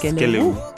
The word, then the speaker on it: Skelewu: Is that how you say it, really? – Yes, Skelewu.